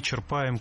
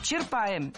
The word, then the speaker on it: черпаем: Черпаем – неверно. Правильно – черпаем. Здесь ударение тоже должно падать на корень слова. Черпать – черпаешь, черпает – черпаем.